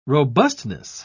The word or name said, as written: robustness